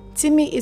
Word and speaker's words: Teamee: Timmy is spelled T-E-A-M-E-E, and I'll be happy to bring you on the show.